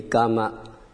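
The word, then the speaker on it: kāma: K-A-M-A, not K-A-M-M-A, Kama. It is Kama. K, the first A is long A, and the second A is short A.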